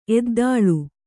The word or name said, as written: ಎದ್ದಾಳು